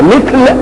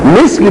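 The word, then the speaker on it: مِثْل: كلمة مثل إذا سمتها الميم والثاء واللام بتنطق كما يأتي، إما أن تنطقها مثل وإما أن تنطقها مثل. مثل بكسر الميم وسكون الساق مش كده؟ ومثل بفتح الميم وفتح الثاء.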